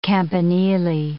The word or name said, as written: campanile